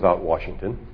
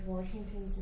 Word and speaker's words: Washington: I think you did a really great job, except you kept mispronouncing George Washington's name. – Georgie Porgy? – Did you call him Washington instead of Washington? – No, there's no R after the A.